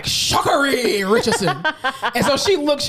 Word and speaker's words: Sha'Carri: Again, we say all the time, if you can say Corsesi and all these other Vankoswaski, you can pronounce Sha'Carri.